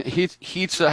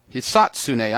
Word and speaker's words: Hisatsune: Hitsa, how do you say his name? – Hisatsune.